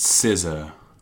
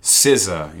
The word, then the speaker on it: SZA: It says, well, I am here, ever the beacon of knowledge, to come to your rescue and inform you that capital S, capital Z, capital A is pronounced SZA.